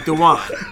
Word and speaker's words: Dwayne: My middle name is Dewan, spelled like Dwayne, but pronounced like Dewan.